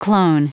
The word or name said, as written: clone